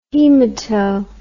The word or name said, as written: hemato